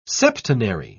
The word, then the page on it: septenary: British English